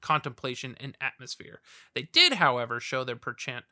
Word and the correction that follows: penchant: how do you say that word? Perchant? Penchant. Penchant? It's not an R, it's an N. Penchant.